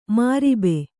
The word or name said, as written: ಮೊರಬು